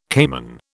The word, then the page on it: caiman: British English